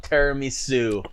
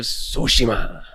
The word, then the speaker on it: Tsushima: Let's go with Ghost of Tiramisu. – Oh, my S. – That's not the right way to say it. S-tier. Go ahead. – Ghost of Tsushima. – There you go.